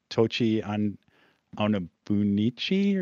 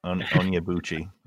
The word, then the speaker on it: Onyebuchi: And I'm going to mispronounce the author's name, Tochi Onabunichi or something like that. I don't know, if you can give me a better pronunciation. – I'm not going to try that. Yep. You did great. Onyebuchi.